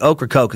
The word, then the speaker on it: Ocracoke: That doesn't look like it's spelled at all to me, but Ocracoke is what they say.